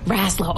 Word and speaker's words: Raslow: They are with the Rascal. Is this how you say it? – Raslow. – Oh, so close. Oh, so close.